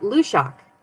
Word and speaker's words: Luidaeg: It rhymes with Love Shack? – Yep, Lushak. It rhymes. – Lushak. Okay. That is not how it is spelled, people. L-U-I-D-A-E-G.